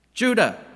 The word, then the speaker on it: Judah: Name starts with a J, rhymes with Buddha. Judah, that's right, Judah.